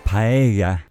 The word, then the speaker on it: paella: In my case, give me a paella. I'm so Spanish. it's pronounced paella, by the way. No, really. Ask my wife.